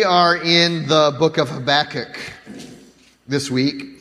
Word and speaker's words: Habakkuk: Mm, okay, Habakkuk, the traditional Hebrew pronunciation is the way we're pronouncing it. It's a little bit more difficult for me, so I got to think about it every single time, enunciating the right syllable.